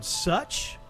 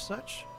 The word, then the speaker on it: Sutch: It's Screaming Lord Such, S-U-T-C-H, Such.